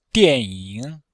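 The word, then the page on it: dianying: dian4ying3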